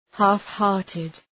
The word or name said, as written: half-hearted